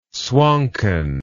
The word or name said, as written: swonken